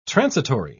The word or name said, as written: transitory